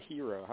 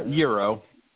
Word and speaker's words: gyro: Now, do you pronounce it gyro or hero? How do you say it? – Euro. – Euro, all right.